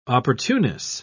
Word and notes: opportuneness: British English

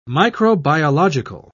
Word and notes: microbiological: British English

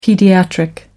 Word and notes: paediatric: American English

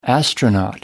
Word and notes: astronaut: American English

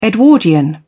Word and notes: Edwardian: British English

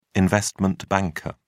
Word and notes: investment banker: British English

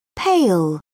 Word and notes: pail: British English

